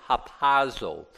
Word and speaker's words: harpazo: Now, the Greek word is harpazo. Harpazo. H-A-R-P-A-Z-O.